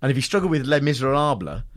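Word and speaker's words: Les Misérables: She doesn't say it right, does and she? Can't, Les Miserables. – Yeah. – Les Miserables. – Les Miserables. – Les Miserables. And, yeah, and all the way through, but her and Matt sort of struggled with the pronunciation of... Matt Bacon called it Le Miserable.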